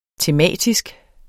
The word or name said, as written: tematisk